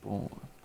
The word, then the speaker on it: Bourne: I've been correct on the pronunciation of Bourne. – Burn? – What else could it be? – What's that Matt Damon one? – Bourne. It's the Bourne identity, Bourne supremacy.